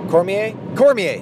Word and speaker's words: Cormier: Cormier.